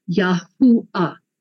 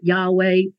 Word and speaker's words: Yahuwah: which is pronounced Yahuwah. Okay. So the Germans call it Yahweh. That's not Hebrew. You know, later on, the Catholic Church called it Jehovah. Again, not Hebrew.